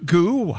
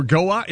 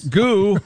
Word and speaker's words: Goux: We're spelling goo, G-O-U-X. – Is that how you pronounce that? – I would think goo or go-ux. It's not go-ux, I can tell you that.